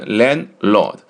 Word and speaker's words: landlord: 那么女地主、女房东呢，就是 landlady, 把 lord 去掉换成 lady, landlady, 那、那么念的时候呢，你念成 landlord, 第一个 d 发音呢有点奇怪，所以说的话呢一般就把它轻化啊，可以不念出来，有一个意识就可以了，念成 landlord。